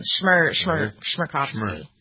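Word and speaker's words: Chmerkovskiy: I would pronounce that Shmerkovsky. How's that? Pretty good? – I think it's an SH sound. Shmerkovsky.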